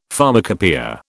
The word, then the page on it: pharmacopoeia: British English